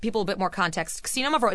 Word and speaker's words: Xinomavro: So even though you hear an X, it's not a Z sound. It's a KS. So Xinomavro. – Xinomavro. And that's spelled X-I-N-O-M-A-V-R-O.